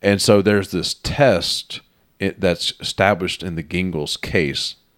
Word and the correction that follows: Gingles: Jingles, actually. I don't know, I said it the way it's spelled, but it's pronounced Jingles.